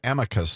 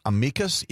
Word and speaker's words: amicus: As always, we take our name from the word amicus, sometimes pronounced amicus. Either one of those is acceptable. That's the Latin.